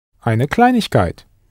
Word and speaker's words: Kleinigkeit: Eine Kleinigkeit. – Eine Kleinigkeit. Note the letters IG, pronounced ich, that sneaked in there between klein and kite.